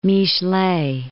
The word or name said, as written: Michelet